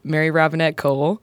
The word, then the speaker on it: Mary Robinette Kowal: My guest today is Mary Robinette Kowal, and she's going to be talking to me about her love of puppetry.